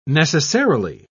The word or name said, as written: necessarily